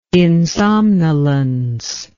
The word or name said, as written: insomnolence